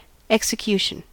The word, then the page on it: execution: American English